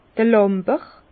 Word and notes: d'r Làmber: Bas-Rhin; Herrlisheim

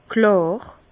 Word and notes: klàr: Bas-Rhin; Herrlisheim

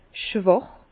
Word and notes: schwàch: Bas-Rhin; Herrlisheim

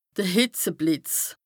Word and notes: de Hìtzeblìtz: Bas-Rhin; Strasbourg

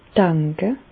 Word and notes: danke: Bas Rhin; Reichshoffen

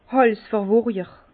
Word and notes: Hàlsverwurjer: Bas Rhin; Herrlisheim

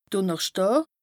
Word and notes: Dùnnerschdàà: Bas Rhin; Strasbourg

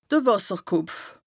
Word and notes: de Wàsserkopf: Bas-Rhin; Strasbourg

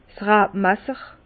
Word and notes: 's Rabmasser: Bas-Rhin; Herrlisheim

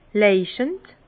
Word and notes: leuchend: Bas-Rhin; Herrlisheim

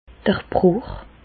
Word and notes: d'r Brùch: Alsatian; Bas-Rhin; Reichshoffen

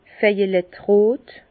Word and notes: vejelettrot: Bas-Rhin; Herrlisheim

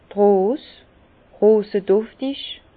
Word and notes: d' Rose, roseduftig: Bas Rhin; Herrlisheim

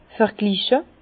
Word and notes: vergliche: Bas-Rhin; Herrlisheim

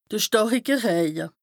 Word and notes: de stàrike Rëje: Bas-Rhin; Strasbourg

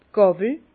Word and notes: d' Gàwel: Bas Rhin; Herrlisheim

